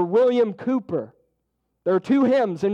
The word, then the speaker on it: Cowper: William Cooper, it's spelled C-O-W-P-E-R.